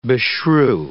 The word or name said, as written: beshrew